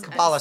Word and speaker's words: Kabbalah: I guess it's pronounced Kabbalah? The Kabbalah. – Kabbalah Center, yeah.